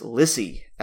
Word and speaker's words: Lissie: my next pick here, this is an artist, I believe her name is pronounced Elizabeth Morus, M-A-U-R-U-S, but her stage name is Lissie, L-I-S-S-I-E.